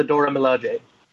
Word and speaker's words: Dora Milaje: Yeah, they are the Dora Milaje.